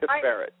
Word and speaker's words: Tiferet: Yeah, I was calling it Tiferet. I knew I'd get it wrong. – Well, Tiferet. T-I-F-E-R-E-T. – No, that's actually another correct pronunciation.